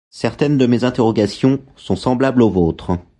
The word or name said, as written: interrogations